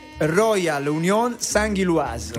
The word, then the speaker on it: Union Saint-Gilloise: Scusa, come si pronuncia? Union San? – Allora, nome completo è Royal Union Saint-Gilloise.